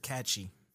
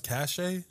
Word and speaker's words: cache: catchy. You clear your catchy folder. – Isn't it pronounced cache? – I don't care what it is. – I thought it was cache. – It's catchy.